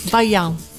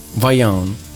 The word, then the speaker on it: Vaillant: Michel Valiant, eh, io proprio all'italiano, mentre lo sa pronunciare ovviamente molto meglio di me, Va- sicuramente. – Vaillant. – Vaillant.